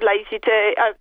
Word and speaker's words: laïcité: And, you know, we've been hearing a lot about the French philosophy of laïcité.